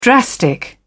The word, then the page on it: drastic: British English